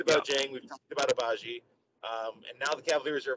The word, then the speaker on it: Agbaji: And then at 13, you got Ochai Ajabi going to the Charlotte Hornets. So Agbaji. You don't pronounce the G.